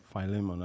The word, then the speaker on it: Philemon: Yeah, Philemon.